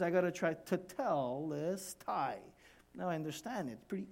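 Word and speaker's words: tetelestai (τετέλεσται): See, it's tetelestai. It is done. That's a Greek word, tetelestai. Can you pronounce it with me? It's pretty hard, huh? Tetelestai. So just, you have to really say tetelestai. Tai.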